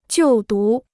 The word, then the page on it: 就读: jiù dú